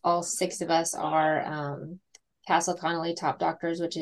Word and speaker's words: Castle Connolly: Castle Connolly top doctor. Am I pronouncing that right? – Yes, that's right.